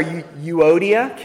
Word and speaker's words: Euodia: But their names are Euodia.